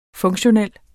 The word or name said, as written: funktionel